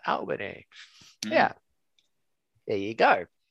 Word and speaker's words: Albany: Do you know how we pronounce that? – How? Not the same way. – We pronounce it different to Giuseppe's. How do we pronounce it? Guess. Try it. – Albany? – Perfect. There it is.